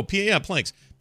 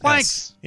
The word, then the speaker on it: PLANX: This person here is Planks, or P-L-A-N-X. How would you say this? Planks? Oh, Planks. P-L-A-N-X-S. – Planks.